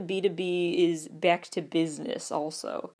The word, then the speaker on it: BTOB: Then there's B2B, which for years I thought was B-T-O-B, but no, it's pronounced B2B.